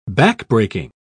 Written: backbreaking

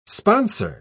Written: sponsor

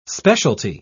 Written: specialty

